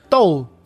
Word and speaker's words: Dou: deine Aussprache, das Do. Niederländisch. – Dou. Das ist dann Doll. Doll. Doll. Genau. Dou.